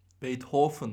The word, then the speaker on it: Beethoven: Sebebi de biliyorsun bu sene Beethoven'ın 250. yaşı kutlanıyor dünyada. Beethoven mı? Beethoven. Beethoven. Aynen. Ludwig van Beethoven. – V'ler F olarak mı okunuyor? – Doğru.